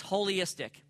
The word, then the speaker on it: Wholyistic: It's holistic, okay? It's spelled W-H-O-L-Y, like holy, like but with W on the front end. So it's like holy, like whole.